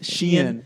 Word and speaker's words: Shein: Sheen or whatever the how do you know how to Shein? – Pronounce it? – Shein.